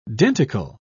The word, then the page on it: denticle: British English